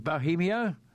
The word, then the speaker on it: Bohemio: During this time, I purchased an Irish thoroughbred called, now is it Bohemio? You pronounce that? – Yeah. Yep. Correct.